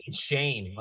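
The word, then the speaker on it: Schoen: The new regime and JQ said, how do you pronounce his last name? – Shane, like Shane. But okay, Joe Shane.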